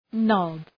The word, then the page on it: knob: nɒb